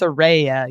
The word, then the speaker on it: Saraya: And it's so great that you have that relationship with Saraya and so many others backstage. And it truly comes across, especially when you have an opportunity. – Which is not Saraya, by the way. – It's Saraya.